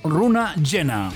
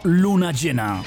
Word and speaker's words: Runa Llena: Cuidado cómo se dice, es runa con R, runa llena, no luna llena. Es que según como lo dices, parece que dices luna llena, y es runa llena.